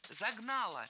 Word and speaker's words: загнало: Загнало. Неверно. Правильный вариант – загнало. И здесь есть одна общая тенденция. В проблемных словах прошедшего времени среднего рода, то есть там, где мы сомневаемся, ударение на окончание не ставим категорически. Поэтому только загнало.